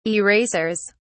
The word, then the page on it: erasers: British English